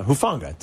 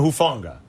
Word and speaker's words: Hufanga: Talafunga, how do you pronounce his name? – Hufanga. Tylanona Hufanga.